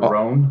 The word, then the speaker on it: RON: It's the RON. – The RON? – R-O-N. – Long O.